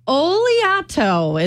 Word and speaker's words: Oleato: Oleato is how it's pronounced, apparently.